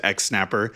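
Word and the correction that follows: Xnapper: Yeah. I think it's probably pronounced snapper, but it's just snapper.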